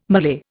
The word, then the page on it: Malay: Indian